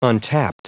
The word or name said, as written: untapped